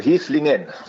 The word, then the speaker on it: Heslingen: Heslich habe ich richtig gesagt und das ist wie gesagt in der Nähe von Zeven, richtig? – Ja, also Heslingen.